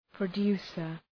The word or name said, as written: producer